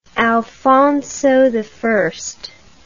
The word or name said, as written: alfonso i